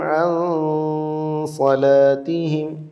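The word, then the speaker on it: അൻ സലാത്തിഹിം (عن صلاتهم): അപ്പോൾ അല്ലദീനഹും അൻ സലാത്തിഹിം അല്ലേ, അൻ സലാത്തിഹിം, ഇതാണ് അതിൻ്റെ ശരിയായ ഉച്ചാരണം. അതിന് ഇഖ്ഫ എന്നാണ് നമ്മൾ പേര് പറഞ്ഞിട്ടുള്ളത്.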